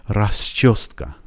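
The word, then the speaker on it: راسچوسکا (расчёска): راسچوسکا راسچوسکا راسچوسکا راسچوسکا راسچوسکا. حرف یو رو اون وسط دیدین؟ مثل این انگلیسی دوتا نقطه رو کلش.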